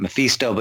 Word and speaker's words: Mephisto: Yeah, Mephisto.